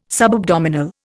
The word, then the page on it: subabdominal: Indian